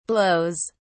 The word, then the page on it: blows: British English